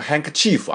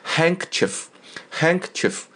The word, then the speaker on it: handkerchief: 啊，他留下了一块漂亮的什么呢？handkerchief, 注意发音，handkerchief。不要念成 handkerchief 啊，不是 handkerchief, 重音在前面 handkerchief 就是手帕，手帕啊。